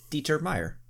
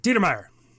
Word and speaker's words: Dieter Meyer: You got to say it like Swiss. You're Swiss. Although I'm Dieter probably not Meyer. Saying it like I'm Swiss. – But when you say it all together, it's like Dieter Meyer.